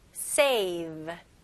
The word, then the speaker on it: save: 여기서는 V 발음 명확히 하셔야 됩니다. save. 이런 식으로 하시면 안 돼요. V 발음은 이렇게요. save. Okay, save. 네, 이가 밑에 입술을 닿아야 됩니다.